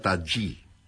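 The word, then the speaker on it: Tajy: Se escribe Tají con J, Y, pero me han dicho que se pronuncia Tají.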